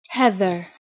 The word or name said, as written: Heather